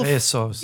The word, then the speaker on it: Bezos: Ik, sorry, Bezos. hij zegt zelf Bezos.